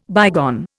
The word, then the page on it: bygone: Indian